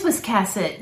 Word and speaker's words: Wiscasset: You have this initial nod towards Wakasset, Maine. – Yes. – I hope we're pronouncing that right. Wiscasset, Maine, rather. I hope we're pronouncing that right. – It is Wiscasset.